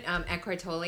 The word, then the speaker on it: Eckhart Tolle: And the present moment, Eckhart Tolle, is that how you say it? – That's how I say yeah.